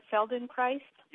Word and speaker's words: Feldenkrais: So in terms of, am I pronouncing it right, Feldenkrais? – Yes.